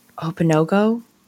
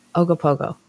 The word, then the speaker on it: Ogopogo: Ogopogo.